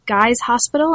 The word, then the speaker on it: Guy's Hospital: And so that fieldwork that you bring up in these early chapters of the book is fieldwork that you did at Guy's Hospital. Am I pronouncing that correctly? – That's right, yeah.